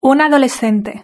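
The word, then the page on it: una adolescente: European Spanish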